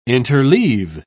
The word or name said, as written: interleave